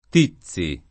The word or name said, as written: Tizzi